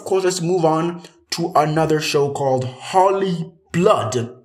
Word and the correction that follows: Holy Blood: Am I saying that right? Holy blood.